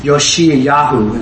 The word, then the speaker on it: Yoshiyahu: Yoshiyahu is what is actually how you pronounce it.